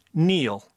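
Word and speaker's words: kneel: The K, which was silent until I spelled it earlier in the clue. Neil.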